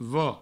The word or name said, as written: vo